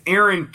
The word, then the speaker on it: Eron: And it's Aaron Keen, but it's spelt E-R-O-N, very similar to Elon, E-L-O-N.